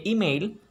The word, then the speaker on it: email: Aquí no sería correcto decir su teléfono y email, pues la E de email se pronuncia como I en inglés.